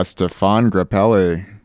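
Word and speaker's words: Stéphane Grappelli: Oh, that would be Stefan Grappelli.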